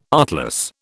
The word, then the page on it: artless: British English